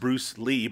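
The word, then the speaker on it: Bruce Li: And to be honest, I've also watched Fist of Fury 2 and 3, the Bruceploitation, I guess, versions, where it's Bruce, I guess it's still pronounced Bruce Lee, but it's Bruce L.I.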